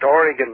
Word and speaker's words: Oregon: And listen, did I say Oregon in the approved way or not? – Pardon? – How do you say it? Say Oregon. – Oregon? – Say it the way you'd say it, so you wouldn't get slapped around. – Just Oregon.